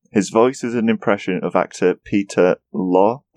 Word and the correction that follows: Lorre: That's how it's pronounced. Peter Lorre.